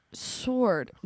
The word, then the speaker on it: sword: Sword. No. Even if I try to make them rhyme. – We don't pronounce the R in sword.